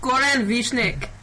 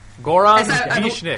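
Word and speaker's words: Goran Višnjić: I can't pronounce his real name, Gorin something. – Gorin Vishnik. – Did you say Goro? – One more time, Goro from Mortal Kombat? – Goran Vishnik. – Goran Vishnik. – I believe that's how it's said.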